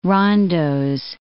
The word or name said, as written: rondeaux